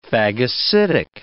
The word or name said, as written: phagocytic